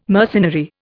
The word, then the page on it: mercenary: Indian